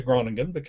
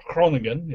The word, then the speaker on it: Groningen: You may have noticed I post a lot about the Netherlands, particularly Groningen, or Groningen, if you get the pronunciation right.